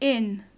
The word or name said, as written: in